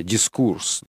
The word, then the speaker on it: дискурс: Опять же, разнообразное применение и использование этого слова «дискурс», или по-французски даже с ударением на последнем слоге, да, «дискурс».